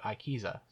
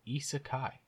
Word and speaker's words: isekai: I've always said it Aikiza, so no, isekai.